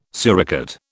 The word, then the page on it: suricate: British English